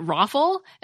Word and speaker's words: ROFL: It's R-O-F-L. I've always pronounced it ROFL as if it were an acronym, but when I surveyed my Twitter friends, I found that about half of them pronounce all the letters, R-O-F-L.